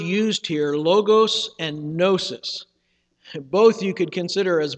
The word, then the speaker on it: gnosis: Comes from that Greek word gnosis. It has a silent G at the beginning, and it means knowledge.